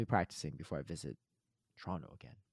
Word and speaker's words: Toronto: If you want to practice your pronunciations, locals say Toronto as Toronto, right? T-R-O-N-N-O. Emphasis on the Tron part. So Toronto. Looking forward to next week.